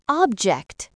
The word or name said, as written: object